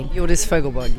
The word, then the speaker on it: Hjordis Fogelberg: Have I said your name correctly? – Hjordis Fogelberg, yes.